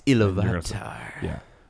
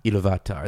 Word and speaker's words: Iluvatar: It's Iluvatar. – Yeah. – Iluvatar. That's just how they say it in the audiobook, and I love it.